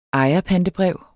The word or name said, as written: ejerpantebrev